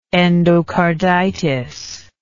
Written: endocarditic

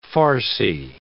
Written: Farsi

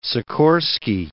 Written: Sikorsky

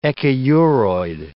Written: echiuroid

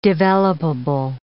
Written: developable

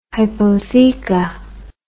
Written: hypotheca

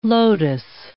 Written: lotos

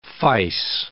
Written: fice

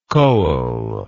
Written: koel

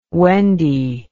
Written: Wendy

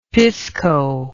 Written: pisco

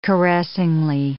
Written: caressingly

